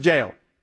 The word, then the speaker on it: Jael: By the way, it's J-A-E-L. – It's pronounced four different ways. I just pronounce it jail or Jael.